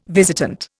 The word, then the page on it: visitant: Indian